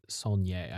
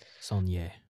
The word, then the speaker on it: Saulnier: I believe it's Jeremy Saulnier. I just looked it up earlier. – Okay, perfect. – Saulnier.